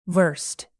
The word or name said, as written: versed